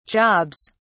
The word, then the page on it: jobs: British English